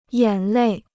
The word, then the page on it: 眼泪: yǎn lèi